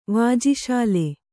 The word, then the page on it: ವಾಜಿಶಾಲೆ: kannada